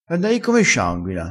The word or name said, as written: scianguina